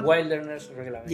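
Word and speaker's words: Wilderness Reclamation: Wilderness Reglamento. Yes. – Entonces. – ¿Lo dije bien, profesor? Sí, sí.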